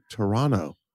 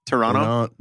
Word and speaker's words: Toronto: And I won't be pronouncing the second T the way I know you like it, not like Sean. And that's why you should come see me. I'll be in Toronto.